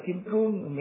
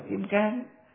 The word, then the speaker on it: kim cương: Cái chữ kim cang đó, kim cang hay là kim cương, miền Bắc đọc là kim cương, miền Nam đọc là kim cang, là để lấy cái chất cứng của kim cương mà ví dụ cho cái trí tuệ bát nhã ba la mật đó.